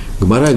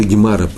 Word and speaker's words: Гемара: Гмара или Гемара по-русски есть. И так, так говорят, есть два варианта произношения.